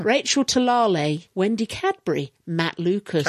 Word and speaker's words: Talalay: Ah, now there's a way of pronouncing Talalay. – This. – Talalay. – No, it's not. – Talalay. – She went on Twitter. It's not Talalay to rhyme with Gallifrey. It's like Tulalay or something like that.